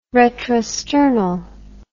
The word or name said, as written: retrosternal